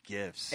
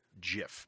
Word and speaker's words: GIF: Well, I argue, I actually agree with you, Jeff, if we're going to talk about gifts, that I think that it's pronounced GIF and not JIF, because it's graphics interface format is what that stands for.